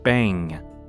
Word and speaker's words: Bang: 但特别注意它的发音，B N G，它结尾会有一个压到鼻头的声音，好，Bang，有一个嗯啊的结尾声，好，Bang，Bang for your buck，所以 Bang 应该是开心的，那在这边代表的是一种兴奋跟喜悦，Bang for your buck，B U C K，第二个 B 开头的字，buck 就是钱，所以是 Bang for the money。